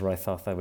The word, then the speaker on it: Raithatha: And then my surname is Raithatha, which is R-A-I-T-H-A-T-H-A.